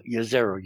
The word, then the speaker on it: Jezero: Jezero, you pronounce it like with a Y, we have been told, which used to be an ancient lake.